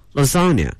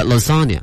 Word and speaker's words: lasagna: 另外这个句子里面有一个吃的哈，叫意大利千层面、它的发音挺怪， lasagna，对，它不是英文啊，应该。